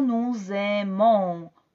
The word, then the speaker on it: nous aimons: Y como escuchas al final, yo digo nous aimons. Oh, oh. Entonces digo este sonido nasal que de verdad no se pronuncia normalmente en idioma español.